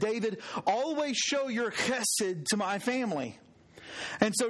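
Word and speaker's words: chesed (חסד): It's pronounced chesed.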